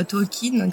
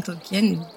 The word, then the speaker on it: Tolkien: Quand, on te dit euh, Tolkien, Tolkien, deux prononciations euh, sont possibles, je vais passer de l'une à l'autre.